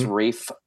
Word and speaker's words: Ralph: It's Rafe.